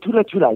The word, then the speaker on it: Thula Thula: And I think that am I saying it right, Thula Thula, or do you pronounce it with a TH? – No, not Thula Thula is correct.